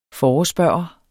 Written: forespørger

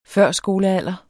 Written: førskolealder

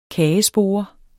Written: kagespore

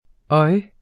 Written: oj

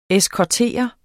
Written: eskortere

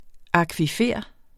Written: akvifer

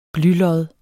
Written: blylod

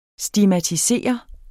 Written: STIGMATISER

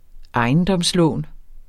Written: ejendomslån

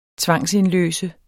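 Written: tvangsindløst

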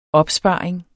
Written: OPSPARINGEN

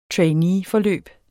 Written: trainee

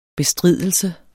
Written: bestridelse

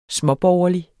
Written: småborgerlig

